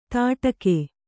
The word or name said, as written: ತಡಕೆ